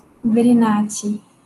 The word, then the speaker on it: Brinati: Brinati.